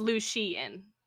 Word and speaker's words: Lucien: But so basically they're talking, and then his um, emissary comes in, Lucien. And did I say that right? I don't want you to like, give me a look. It's Lucien.